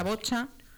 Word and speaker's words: boccia: Sí, creo que se dice la bocha. Se escribe con dos Cs y A, pero creo que se dice bocha.